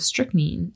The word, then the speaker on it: strychnine: he had found traces of strychnine in them. – Strychnine, sorry. Have I been saying it wrong? – No, you said it strychnine. Okay. You said it correctly the first time.